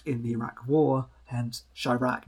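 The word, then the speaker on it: Chi-Raq: Chirac, as in to rhyme with Iraq about Chicago.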